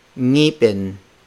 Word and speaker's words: ngipin: The ng consonant can also be placed at the start of the word. This is the most challenging among foreign speakers. They have difficulty pronouncing the ng sound at the start of the word. For instance, the word for tooth, ngipin, ngipin, ngipin.